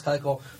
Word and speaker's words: Keuchel: It's taken me 15 episodes to learn that Dallas Keuchel's name is pronounced Dallas Keuchel, but I'm going to agree and pick him as the AL Cy Young.